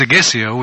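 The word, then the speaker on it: Seghesio: I noticed Segesio, which is, I think that's how it's pronounced, yep.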